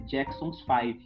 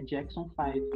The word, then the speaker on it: Jackson Five: É, depois que, que eu acabei conhecendo o Michael Jackson, ela sempre pronunciava é, Jackson's Five, mas a pronúncia correta é Jackson Five.